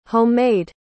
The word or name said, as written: home-made